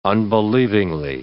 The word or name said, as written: unbelievingly